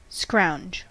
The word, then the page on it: scrounge: American English